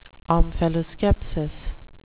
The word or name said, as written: omphaloskepsis